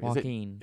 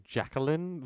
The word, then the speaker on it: Joaquin: So, how do you pronounce his name? – Joaquin. – It's Joaquin. – Joaquin. – Okay, I've been saying Jacqueline because Where's the L?